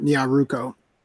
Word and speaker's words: Nyaruko: I'm pretty sure you just said Nyakuro and not Nyaruko.